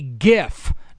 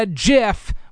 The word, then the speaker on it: GIF: The little moving graphics on social media and on the internet and things like that is pronounced with a hard G. So that would make it a GIF, not a GIF, which is peanut butter.